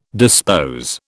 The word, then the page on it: dispose: British English